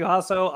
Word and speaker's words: Jasso: Jasso, I'm butchering the hell out of it, and that's okay. He likes me anyway. – Can Sometimes. You introduce me? Yes, Sometimes. my name is Matthew Jasso.